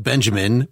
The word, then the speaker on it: Benjamin: This Walter Benjamin essay, spelled Benjamin, pronounced Benjamin, was, I believe, the first thing that she cited in there.